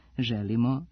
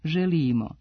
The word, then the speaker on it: želimo: U književno-jezičkoj akcenatskoj normi srpskog jezika prihvaćene su obe varijante, te je u redu da nešto želimo, ali i da želimo.